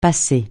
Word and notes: passer: francais

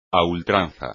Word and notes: a ultranza: espagnol

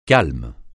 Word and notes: calme: francais

